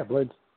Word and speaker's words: Blaydes: How do you say it? – Yeah, Blaydes.